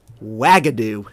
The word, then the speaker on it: Wagadu: You want to give this pronunciation a try? – Ooh. Ugadu? – Wagadu. – I like that.